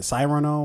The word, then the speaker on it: Cyrano: Um, uh, Serrano, uh, Cyrano, or I don't know how to pronounce it. C y r a n o, uh, and it's about Serrano de Bergerac.